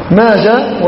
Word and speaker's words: ماجه: فماجة وسيدة ومندة كل هذه تنطق بالهاء الساكنة، وترسم وتنطق بالهاء الساكنة،